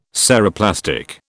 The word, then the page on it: Ceroplastic: British English